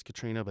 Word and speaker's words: Catriona: I think it's Katrina, but it's spelled like Catriona.